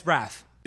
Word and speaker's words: Braff: Is it Braff? – Yeah. – But I'm a fan of his. I'm a fan of his. I've been saying his name wrong. – Yeah, it's Braff.